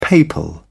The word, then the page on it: papal: British English